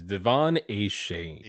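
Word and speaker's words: De'Von Achane: Zach Charbonnet, Dalvin Cook, who is now officially with the Jets, David Montgomery, James Cook, Devin Achane. – I believe the proper pronunciation is Devon Achane. – Achane. It's these H's, man. These CHs that are giving me problems.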